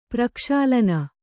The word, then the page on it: ಪ್ರಕ್ಷಾಲನ: prakṣalaṇa